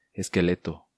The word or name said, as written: Esqueleto